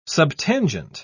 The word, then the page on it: subtangent: British English